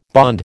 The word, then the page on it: bond: American English